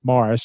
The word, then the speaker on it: Maurice: But we are going to pay tribute to Morris Petty, Maurice Petty. Uh, everybody pronounces it Morris, so we're going to go with Morris.